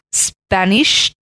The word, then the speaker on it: Spanisch: And it is called Spanische Windtort. – I'm sure you pronounced that a little wrong. – Yeah. So the English translation is actually Spanish Windtort. But S-P-A-N-I-S-C-H-E. – Yeah, Spanisch. – Spanisch. Windtort.